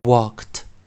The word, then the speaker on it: walked: 以及我们的 w, w, w, w, walked, walked。 walked，注意是在发 kt，walked，啊，l 没有发音。